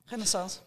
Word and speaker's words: Renaissance: En dan heb ik er nog één product tussen uitgepikt en dat is de, de, de het Renaissance zadel, zeg ik het goed? Renaissance? Renaissance.